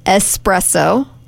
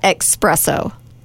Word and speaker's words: espresso: Espresso. – Espresso. – Not expresso.